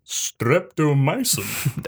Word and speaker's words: streptomycin: So the hospital was fully functional as a tuberculosis hospital until the introduction of, I'm going to say this wrong, streptomycin, which is the cure for tuberculosis. – Streptomycin.